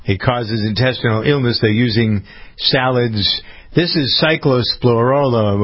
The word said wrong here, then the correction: cyclospora: or I never pronounce that right, cyclospora.